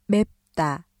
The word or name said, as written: 맵다